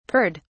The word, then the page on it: purred: British English